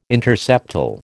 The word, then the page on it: interseptal: American English